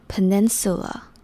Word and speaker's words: peninsula: 那 Peninsula 的 PE 就是发成 P， E 是发耳的音。接下来 ,nin 是 nin, 然后 sula 的 su 是短一点点 ,peninsula, 最后的 la 不用念成 la, 只要轻轻念成 le 的感觉就可以，所以 peninsula, 那念快一点点 Peninsula。